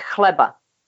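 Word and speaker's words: chleba: Actually, it's so C-H, is a one letter in Czech language. Oh. And it's pronounced h, like it's hard in your throat. So it's kleba.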